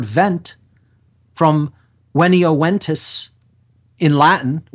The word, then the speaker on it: venio: There's really not a V sound in classical Latin. It's pronounced with a W. So it's V-E-N-I-O is the verb, and it's pronounced venio, venio, veniere, ventus. And that means to come or to become present, to arrive.